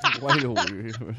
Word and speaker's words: Willoughby: Los hermanos Willy Woody, Will Woody, Will Willow, Willoughby. – Willoughby. – Willoughby. Los hermanos Willoughby.